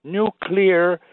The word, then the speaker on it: nuclear: Now, the word nuclear has been mispronounced, by presidents, including Eisenhower, Carter, George W. Bush, who they say used to do it deliberately, for popularity reasons. Now, in your opinion, which is mine, actually, I believe that due to the fact that they did not see the word, the spellings, mm-hmm. They thought nuclear was spelled NUCULAR rather than NUCLEAR.